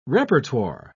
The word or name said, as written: repertoire